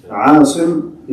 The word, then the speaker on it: Asim: Asim is the protector. Asim is a common name. Asim by... – Rhyming with Qasim, Abu Qasim.